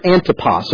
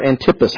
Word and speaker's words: Antipas: One of those sons was Herod Antipas, or Antipas, however you want to pronounce it.